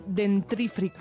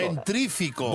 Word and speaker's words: dentífrico: Me pasa a mí con dentífrico. Dentri, dentífrico. – Dentífrico. Dentífrico, estás cambiando una R y añadiendo una.